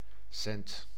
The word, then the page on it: rond: /ʁɔ̃/; France